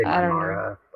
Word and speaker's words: Mimara: Speaking of Nicanian and him teaching, so is it Mimira? The pronunciation? – I say Mimara. – Yeah, I say Mimara as well.